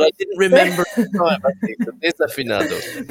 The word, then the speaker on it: Desafinado: Okay, I'm going to correct you there. It's Desafinado.